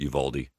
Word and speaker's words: Uvalde: I believe it's Uvalde. Is that right? – If you're, yeah, in Texas, I've always heard it Uvalde. But if you're a Spanish speaker, it's Uvalde. But yeah, it's Uvalde.